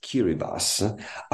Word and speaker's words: Kiribati: The name of the country is written uh, Kiribati with TI at the end, but the pronunciation is Kiribati,